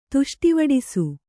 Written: ತುಷ್ಟಿವಡಿಸು

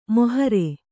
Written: ಮೊಹರೆ

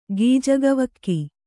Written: ಗೀಜಗವಕ್ಕಿ

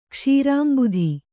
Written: ಕ್ಷೀರಾಂಬುಧಿ

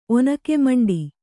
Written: ಒನಕೆಮಂಡಿ

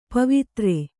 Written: ಪವಿತ್ರ

